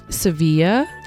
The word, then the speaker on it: Sevilla: Sev- Sevilla. That's how you say it, actually. Don't get angry, Spain.